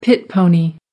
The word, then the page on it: pit pony: American English